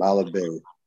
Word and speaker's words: Malabet: Malabet, I hope I got that right. Your last name, because I never say your last name. Right. the program. Uh, how you doing? How you doing this evening, Monique? – I'm doing good. I'm doing good. And yes, you are correct on last name.